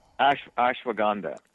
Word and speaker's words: ashwagandha: And now how about the benefits of ginseng and something called ashwagandha? Is that how to say it? – Ashwagandha.